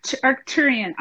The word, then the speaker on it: Arcturian: Arcturian? Is that right? – Arcturian.